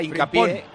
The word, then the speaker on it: Hincapié: Y juega Hincapié, que está bien pronunciado porque es sudamericano.